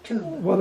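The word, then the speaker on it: tub (טוב): T-O-O-B, tub. That's the pronunciation.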